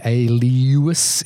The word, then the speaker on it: Aelius: And Alias wanted to, and I hope I'm pronouncing that correctly, Alius. It's spelled A-E-L-I-U-S.